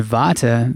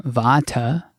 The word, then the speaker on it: vata: It's vata, and vata, V-A, long A, ah, vata, is the principle of movement.